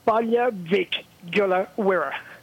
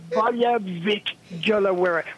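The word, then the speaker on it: Baile Mhic Giolla Mhuire: How do you pronounce that? – Balia Vic Gula Wira. – That's... – Balia Vic Gula Wira, which is the Irish for Bally Kilmurry.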